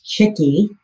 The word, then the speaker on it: Chicke: Well, it is pronounced Chicke, and it has been my name virtually since I was born, although it was not my birth name.